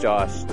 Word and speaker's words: Josh: R-I-C-H-A-R-D. – And yourself? – My name is Josh, J-O-S-H.